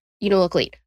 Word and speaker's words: Unalakleet: I was like, you know, no, Unalak, no, Unalakleet. Okay, got it. Like mm-hmm. I was struggling with it because it's the way it's spelled and the way it's pronounced are a little different.